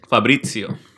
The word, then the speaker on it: Fabrizio: The how do you say his name? Fabrico. – Fabrizio. – Fabrizio. Fabrizio. That was still horrible.